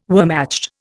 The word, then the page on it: Wehrmacht: Indian